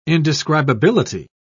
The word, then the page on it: indescribability: British English